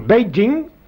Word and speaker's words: Beijing: Si bien que la traduction se lit pour lire, Beijing, comme on doit le prononcer, il faut le Beijing. Alors là, si vous le prononcez avec l'accent anglais, c'est parfait.